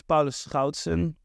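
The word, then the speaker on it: Paulus Schoutsen: Yeah, my name is Pallas Skoutsen. And the G is always a bit difficult to pronounce.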